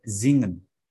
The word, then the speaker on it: singen: singen ah hampir ya Cuma tinggal dikit, yaitu menjadi Zingen. Coba, Zingen. Zingen. Jadi S itu kalau di Jerman bacanya Z ya, teman-teman.